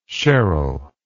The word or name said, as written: cheryl